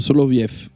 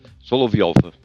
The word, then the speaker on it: Soloviev: Soloviev. Così si pronuncia Soloviev. Ah, signor. Soloviev.